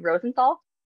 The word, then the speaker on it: Rosenthal: Today, I have Daniel, how do you say your last name, Rosenthal? – Close enough. It's a silent H, so Rosenthal, but like Rosenthal, Rosenthal, whatever way it comes out, it's totally fine.